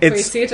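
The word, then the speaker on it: Quaesita: So data is what we give, and that word, which I don't know how to pronounce either, but you know, let's picture it American, and quesita is fine. That's quesita.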